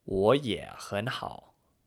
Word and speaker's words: 我也很好: This is pronounced 我也很好, which follows a kind of up and down rhythm as you pronounce the second tone, the third tone, the second tone, and finally the third tone.